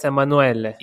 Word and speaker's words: Emanuele: Hey, Emanuele. Is it that's the name, right? Emanuele. Yes, that's the perfect Italian pronunciation.